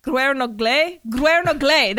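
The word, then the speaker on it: Gwernogle: They are a couple that have recently moved to Guernogel. no, Guernogle. That's how you say it. Yes, Welsh is fucking hard.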